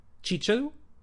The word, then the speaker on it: chícharo: A minha avó usava xíxaro, pronunciado chícharo. Em Ventral dos Montes o, ainda temos o som ch, que já se perdeu no resto do país.